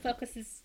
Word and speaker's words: foci: And this really, there were different foci, is that how you say it? In yeah.